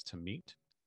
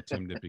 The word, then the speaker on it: Timnit: So we always call her Timnit because that's the way that her name is spelled, T-I-M-N-I-T. And so I just always say Timnit, Timnit, Timnit, but it's technically Tamit.